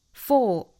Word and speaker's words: for: for 和 the 弱读，for 弱读成 f。